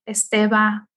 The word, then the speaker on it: Esteva: It's Desiree Esteva Cara. – Can you spell that, please? – Oh, yes, of course. It's D-E-D-E-S-I-R-E-E Esteva E-S-T-E-V-A Cara, C-A-R-A.